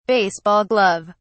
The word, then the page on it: baseball glove: British English